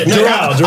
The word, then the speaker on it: Doral: Doral. – I'm at Daryl.